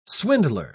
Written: swindler

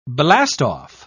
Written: blastoff

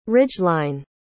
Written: ridgeline

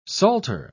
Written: psalter

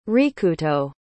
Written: rikuto

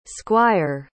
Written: squier

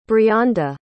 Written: brianda

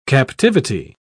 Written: captivity

